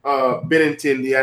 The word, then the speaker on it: Benintendi: Andrew Nintendo. Benintendi, I know how to pronounce it.